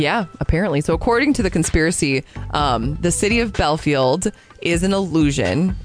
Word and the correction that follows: Bielefeld: It could be Bielefeld. – I think it's Bielefeld. That's how I pronounced it, I think.